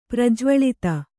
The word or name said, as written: ಪ್ರಜ್ವಳಿತ